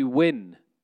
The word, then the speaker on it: Nguyen: It's spelled N-G-U-Y-E-N, but the first five letters are silent. There's an invisible W and an invisible I. – So you do have. – And then you say the N at the end.